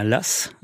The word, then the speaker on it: lacs: Alors, un lacet, c'est un, nœud coulant, c'est un las, hum. Normalement, ça s'écrit L-A-C-S, oui